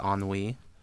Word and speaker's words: ennui: Is that how that word is pronounced, ennui? – Yeah. – Yes.